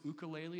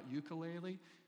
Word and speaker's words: ukulele: He had in his TED talk, he talked about learning to play the ukulele, which I always mispronounced it. You know, it's ukulele and not ukulele. Slight difference.